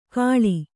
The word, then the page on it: ಘಳಿ: Kannada